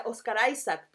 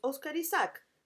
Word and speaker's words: Oscar Isaac: Em, yo le decía Oscar Isaac, po, no po. – Hasta que él mismo lo pronunció. Hasta que él mismo lo pronunció, es Oscar Isaac. Ah, así tal cual.